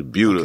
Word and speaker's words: Buda: Because they get on me because I say it wrong. And they're like, nah, you're saying it wrong. You got to say it right. – Yeah. – It's Buda.